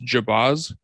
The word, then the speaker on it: Jabbaz: So his name is Rob, I think his last name is pronounced Jabbaz. It's J-A-B-B-A-Z.